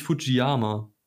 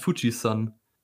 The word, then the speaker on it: Fuji-san: Das ist nämlich, es heißt überhaupt nicht Fujiyama, sondern es heißt Fuji-san. Ist einfach so, das ist ein Übersetzungsfehler, weil das Zeichen für Berg sowohl aus Yama als auch als San ausgesprochen werden kann. Und es heißt aber San. Also ja, Fujiyama ist falsch, liebe Freunde.